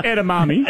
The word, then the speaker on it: edamame: Edamame.